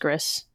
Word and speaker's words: Gris: Gris is Gris. Yes. I always called it Gris. – No, I think it's pronounced Gris.